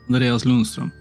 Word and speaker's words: Andreas Lundström: It was a Kickstarter gift, but that is by Andreas Lundstrom. And yeah, I can. – Go for it. What is it? – Andreas Lundstrom. – There we go.